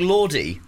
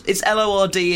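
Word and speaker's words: Lorde: It's L O R D E. The New Zealand guy is very fashionable. – It's Lord, isn't it?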